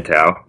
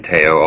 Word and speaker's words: Tao: Did I say your name right? I should have asked first. – Yeah, it's Tao.